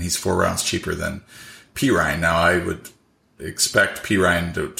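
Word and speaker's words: Perine: And it's going to be because of Pirine here, Samaje Pirine. – Samaje. So I have his name spelled P-E-E-R-Y-N-E now. So I will remember how to pronounce it. Pirine.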